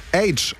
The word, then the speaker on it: Aitch: Jetzt mit neuer Musik, die kommt vom britischen Rapper H, also ausgesprochen wie das englische H, also das von TH. Ihr checkt, ne? Aber nur ein H wäre als Künstlername vielleicht zu kurz gewesen, deswegen wird es A-I-T-C-H geschrieben. Ja,